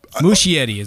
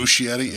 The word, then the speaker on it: Muschietti: I think we can all agree that, like, again, I'm even willing to go with Andy Machete that, like, or was Machete? Is that his name? I apologize if I'm butchering his name. – Muschietti is what I'm going with. Muschietti, yeah.